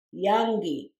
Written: yanggi

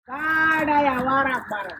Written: gaardeya warrag barrern

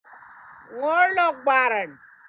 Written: ngoorloog barrern

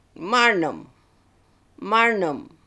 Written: Marnem

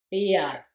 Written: biyarr

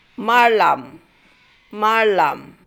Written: Marlam

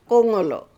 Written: Goongooloo

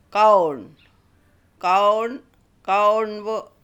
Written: Gawoorn gawoornboo

